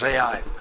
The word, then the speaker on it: Ai: To say, to spell it is to say it. Just two letters, Ai.